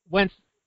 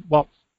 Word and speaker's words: Waltz: Well, Wentz, how do you say his name? Waltz.